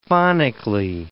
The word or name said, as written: phonically